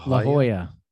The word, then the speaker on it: La Jolla: La Jolla.